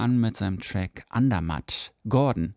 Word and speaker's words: GRDN: Er hat nämlich alle Vokale aus seinem Namen entfernt und heißt jetzt nur noch Gordon ausgesprochen, aber G-R-D-N. Punkt.